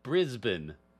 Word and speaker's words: Brisbane: Yes, one of our listeners on YouTube, I think, was like, you pronounce it Brisbane. So I will say Brisbane in the correct sense.